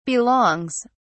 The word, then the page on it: belongs: British English